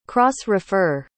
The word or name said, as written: cross-refer